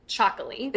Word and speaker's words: Txakoli: It's spelled really interesting, T-X-A-K-O-L-I, and you pronounce it Chocolate.